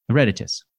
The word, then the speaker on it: Eruditus: The website is Ereditus. I feel like I'm mispronouncing your company name. Ereditus. – Eruditus.